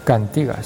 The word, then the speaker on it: Cantigas: Cantigas, se dice así, que ahí está la tilde.